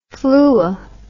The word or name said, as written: fluo